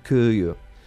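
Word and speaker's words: Kyiv: Aquesta pronúncia és la ucraïnesa, que sembla una mica com Kiev. – Kiev. Yeah, i l'altra, la del rus, és més o menys Kiev. Kiev. Però no és una E, tampoc.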